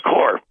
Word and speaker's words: Corps: C-O-R-P. Everybody knows that's pronounced core.